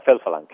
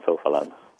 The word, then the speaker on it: Filfilan: Filfilan. Karam Filfilan.